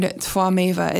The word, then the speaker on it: Tvameva: And I don't know why, I just really like the word Tvameva. It's spelled T-V-A-M-E-V-A. And the T-V at the beginning, I believe, is pronounced more of a Tva, like it's a cross between a W and a V sound, Tvameva.